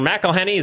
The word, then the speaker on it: McElhenney: So, damn it, Ryan Reynolds and Mr. McElhenney, is that how you say his name, right? – Yeah.